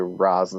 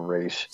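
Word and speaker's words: Ra's: Yeah, but in the grounded reality he tried to do it in, it makes sense to do Roz instead of Raish. – Still stupid. It's not stupid that they call it Roz. It's stupid that he himself calls himself Roz.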